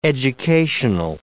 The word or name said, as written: educational